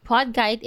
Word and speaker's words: PodKite: So PodKite is spelled as P-O-D-K-I-T-E. As in kite, so PodKite.